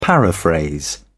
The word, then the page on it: paraphrase: British English